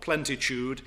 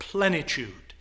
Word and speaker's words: plenitude: We come across this word although we often mispronounce it in one of our hymns, when we read of God in all his plenitude of grace. Not plentitude, but plenitude.